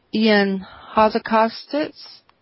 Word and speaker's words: Ion Hazzikostas: It's going to feature lead quest designer Dave Kosak, lead encounter designer Ion Hazzikostas, I know I'm mangling his name. – Actually, you got his name right.